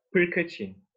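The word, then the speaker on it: Prkačin: And how do you pronounce his last name? I've heard it Perkacin. – Yeah, Perkacin. – Perkacin.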